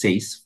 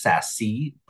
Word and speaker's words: SASE: I am the CEO and Executive Director for the Society of Asian Scientists and Engineers, pronounced SACE for short, like vase. Some people might call us SASE, but we're really SACE.